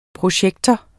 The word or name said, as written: projektor